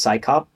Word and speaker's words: CSICOP: So, you know, they had this big, long acronym, and it was literally pronounced, and in their documents, like in Skeptical Inquiry magazine, they say it's pronounced PSYCOP.